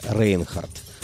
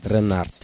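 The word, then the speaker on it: Reinhardt: Пожалуй, единственной джазовой суперзвездой тех лет, родом из Старого Света, был французский цыганский гитарист, которого мы традиционно называем Джанго Рейнхардт. На самом деле его фамилия произносится примерно как Ренард.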